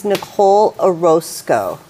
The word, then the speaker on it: Nicole Orozco: My name is Nicole Orozco, and I am the co-founder and CSO of The Flame, which is a physical newspaper.